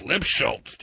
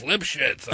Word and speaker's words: Lifschultz: But Ed McMahon kept mispronouncing Jeff Lifschultz, Jeff Lipschitz. I was like, I couldn't take it anymore.